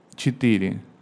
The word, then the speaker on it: четыре: Um pouquinho diferente. Você mudou a ordem das sílabas. É четыре. Четыре. – Tetire. – Tchê. A primeira letra é tchê, como o tchê dos argentinos e gaúchos.